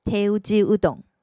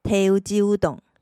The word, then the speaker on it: 手打ちうどん: 手打ち，手打ち，这代表该店所使用的面条都是手工制作而非机械制造，所以手工乌龙面就念成了“ 手打ちうどん”。手打ちうどん，手打ちうどん，手工蕎麦面就是“ 手打ちそば”。